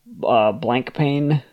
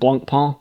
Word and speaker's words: Blancpain: I incorrectly said Blancpain, which is the dumb American way of saying it. It's actually a French word, Blancpain, which I took 4 years of French, should have known that, but was just too lazy.